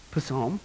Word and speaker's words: Psalm: For those of you hooked on phonics, how would you pronounce that once again? Psalm, that's right.